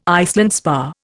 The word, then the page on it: Iceland spar: Indian